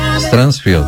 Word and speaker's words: Strangfeld: Como é que se pronuncia? – É o Telson Rodrigo, acho que é o Strangfeld. – Strangfeld. Isso. E a Marlene Schmidt.